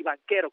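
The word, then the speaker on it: Banchero: Del otro lado, del lado de Orlando, viene Paolo Banquero, así, Banquero, como se escucha, se escribe con CH, el italiano se pronuncia Banquero,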